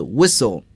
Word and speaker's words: whistle: 接下来的两个词是和奥运会的比赛项目相关的，一个是摔跤 Wrestle 大家注意这个词啊 WR 开头，那么往往有时呢这个 W 是不发音的，比如说这个吹哨子 Whistle 啊，像这里 Wrestle W 都是不发音的，在英语中被称作 Unvoiced 啊，不发音摔跤。